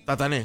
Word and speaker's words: Tatané: La localidad que mencioné de Formosa era Tatané. – Tatané, exactamente. Tatané, con acento en la, con tilde. En la E final.